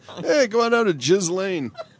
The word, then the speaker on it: Ghislaine: G-H-I-S. – No relation. – No, that's her first name. G-H-I-S-L-A-I-N-E. I mean, that's how I would pronounce that, right? Ghislaine. – Yeah. – Right? – Yes.